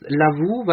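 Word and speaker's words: Lovoo: Lovo? – Ich bin der Meinung, es heißt Lavu, weil es ein Kofferwort aus Love und Yahoo ist.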